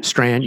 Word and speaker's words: Strachan: For instance, Al Mohler retweeting Owen, I don't know how to say his last name, Strachan, is that his name? – Strand, yes.